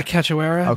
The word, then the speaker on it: Cachoeira: How do you say her last name? Cachoeira? – Yeah, Cachoeira.